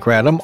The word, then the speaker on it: kratom: It is called kratom. That's spelled K-R-A-T-O-M, kratom.